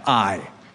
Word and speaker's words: Ai: That's how you pronounce that little town, Ai,